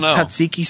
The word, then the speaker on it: tzatziki: You grow herbs, you make tzatziki sauce, saying it right.